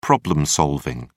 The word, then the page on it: problem solving: English; British English